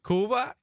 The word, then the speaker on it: Cuba: Did I pronounce that right? Because everyone says Cuba, but there is Cuba, right? – Cuba.